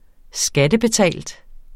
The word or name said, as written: skattebetalt